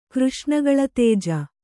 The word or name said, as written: ಕೃಷ್ಣಗಳತೇಜ